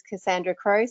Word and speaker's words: Cassandra Crowe: But in terms of introducing myself, my name's Cassandra Crowe, so not too tricky to pronounce that one.